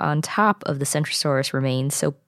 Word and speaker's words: Centrosaurus: Centrosaurus is with a C, and Kentrosaurus is with a K, and also, as you can tell, they're pronounced differently.